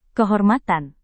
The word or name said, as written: kehormatan